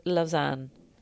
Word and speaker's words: Lausanne: Are we confident about the pronunciation of Lausanne? – Yes, I think so.